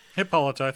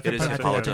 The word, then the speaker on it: Hippolyta: Hippolyta. – Hippolyta.